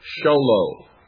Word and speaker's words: Show Low: Sholo, that's spelled S-H-O-W-L-O-W. Sholo, Arizona, 85901.